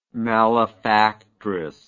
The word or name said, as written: malefactress